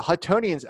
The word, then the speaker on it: Huttonians: So in the early 1800s, the Neptunists outnumbered the Huttonians, that's how you pronounce it, by like 10 to 1.